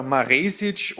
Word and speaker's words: Maresic: Ob man denn jetzt wirklich Maresic ausspricht oder Maresic, oder wie spricht man den eigentlich aus, den Innenverteidiger? – Na, eigentlich sagt man Maresic, aber das und andere Dinge wird er uns heute verraten, wenn er bei uns zu Gast ist im Podcast.